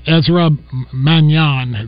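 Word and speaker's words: Manjon: Ezra Manyan is how you say it.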